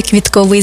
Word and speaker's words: квітковий: Я прямо відчуваю цей квітковий запах. До речі, правильний наголос саме квітковий, бо стосується квітів. А квіткові – це про органи розмноження за допомогою квіток.